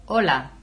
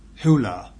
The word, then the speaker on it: hola: Hola. – Yeah, not hula.